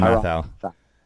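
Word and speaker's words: Mathieu: I always mispronounce his last name. Matthau. How do you say it? – Tyron Matthau. Tyron Matthau.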